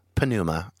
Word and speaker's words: pneuma: And so if that's how we are interpreting pneuma, non-material, pneuma, I guess, I don't know if I say it right. – Yeah, yeah. Pneuma's fine. – P-N-E-U-M-A, I believe is the spelling.